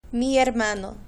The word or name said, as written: mi hermano